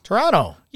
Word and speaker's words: Toronto: One of our recommendations, official recommendations of the Better Together segment of the Food About Town podcast is Toronto is good. – Toronto. – Yeah. – Go there. – So back to... – Say it right. Don't put a T in it.